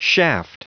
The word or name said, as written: shaft